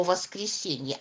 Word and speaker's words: воскресенье: Давайте произнесем слово «воскресенье» и поставим ударение. Позовем его. «Воскресенье». Куда ударение падает? На гласную «е».